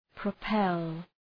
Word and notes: propel: prə’pel

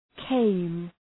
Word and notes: came: keım

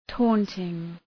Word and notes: taunting: ‘tɔ:ntıŋ

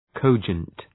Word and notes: cogent: ‘kəʋdʒənt

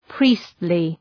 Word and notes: priestly: ‘pri:stlı

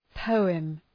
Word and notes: poem: ‘pəʋəm

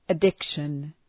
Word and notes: addiction: ə’dıkʃən

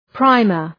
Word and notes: primer: ‘praımər

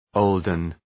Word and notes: olden: {‘əʋldən}

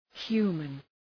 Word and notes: human: ‘hju:mən

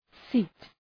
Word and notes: seat: si:t

